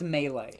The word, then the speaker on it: melee: How do you say that word? – I believe it's pronounced melee.